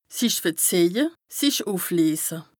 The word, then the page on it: sich verzìehje, sich ùfleese: Bas-Rhin; Strasbourg